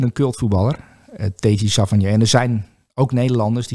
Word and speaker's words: Savanier: Savanier, zeg ik dat goed? Savanier, ja.